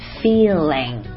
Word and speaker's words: feeling: 妈妈爸爸和宝贝儿们要注意，不要把这个单词和表示感受感觉的那个单词的发音给弄混了。感受感觉的那个词是 feeling， feeling，嗯，这个地方是一个长元音 E feeling， F E E L I N G feeling。